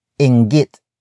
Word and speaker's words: inggit: And that is inggit.